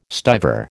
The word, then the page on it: stiver: American English